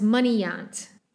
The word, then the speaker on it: Munigant: Because as well, so Bert says, like, oh, are you Dr. Munigant? And he's like, no, it's Munigant is how you pronounce it. So vaguely Frenchy, which makes sense for Canada, I suppose.